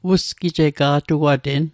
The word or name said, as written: usgijega’tuaten